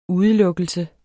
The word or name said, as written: udelukkelse